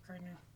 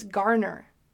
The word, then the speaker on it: Garner: It's actually Jennifer Gardner, and I just wanted to see if you'd pick up on it, because Lucas gets so mad at me when I say it like that. – Gardner? – Did you know that? It's Garner.